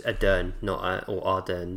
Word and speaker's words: Ardern: And I just want to say it's Ardern, not Ahern. I got her name wrong.